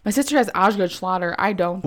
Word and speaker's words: Osgood Schlatter: I think it's Osgood Schlatter. I'm going to type it in. Osgood Schlatter.